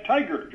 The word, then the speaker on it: TIGHAR: And incidentally, we pronounce that tiger, just like the animal.